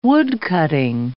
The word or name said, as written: woodcutting